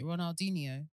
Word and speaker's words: Ronaldinho: Is it Ronaldinho? I never know how to pronounce his name. – That is the name.